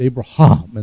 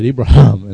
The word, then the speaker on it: Abraham: In the Jewish language, they have that huh sound, and they very prominently pronounce it, Abraham, and they really, you know, down from the down from deep in the inside, Abraham, and they bark it out.